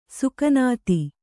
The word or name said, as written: ಸಘಾನತೆ